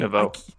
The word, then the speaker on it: Neveu: Neveu.